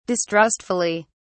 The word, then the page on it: distrustfully: British English